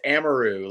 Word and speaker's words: Amaru: It's a lot of people say Amaru or Amaru or I'm like, man, it's like it's Amaru, like, you know.